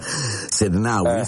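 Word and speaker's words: Sednaoui: Elisa Sednaui, si pronuncia così, sì, che nessuno allora, può farti star male senza il tuo permesso, no? – Esatto.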